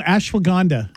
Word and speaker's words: ashwagandha: Ashwanga? – No, ashwagandha.